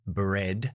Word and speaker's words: bread: No es tan común porque tiene otras pronunciaciones, como por ejemplo el pan, bread, que lo pronunciamos como una e solamente, pero también puede ser como la palabra genial,